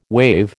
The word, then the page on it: wave: American English